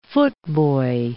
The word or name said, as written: footboy